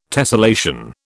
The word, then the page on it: tessellation: British English